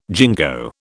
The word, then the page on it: jingo: British English